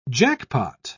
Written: jackpot

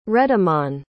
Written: redamann

